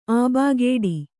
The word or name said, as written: ಆಬಾಗೇಡಿ